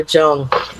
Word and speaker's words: Jung: It's Jung. It's a hard J, but it's okay. Everyone says Young. It's like been a thing my whole life.